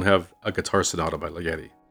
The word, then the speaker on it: Ligeti: Ligeti. I've heard people pronounce it in all sorts of ways. So I'm going to stick with Alex's pronunciation and say Ligeti.